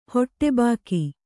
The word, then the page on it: ಹೊಟ್ಟೆಬಾಕಿ: hoṭṭe bāki